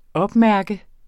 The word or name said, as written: opmærke